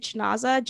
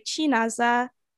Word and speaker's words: Chinaza: My first name is, like, even my parents, like, we'll pronounce it Chinoza just for easiness, but like it's actually pronounced like Chinaza, so it's more, there's more intonation and stuff.